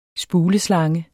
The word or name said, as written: spuleslange